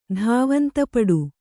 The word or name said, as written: ಧಾವಂತಪಡು